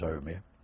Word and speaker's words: Omi: And then they marched us to the station, and I ended up in, it's spelled Aomi, A-O-M-I, but often it is printed in books as Omi. The Japanese do pronounce it as Omi.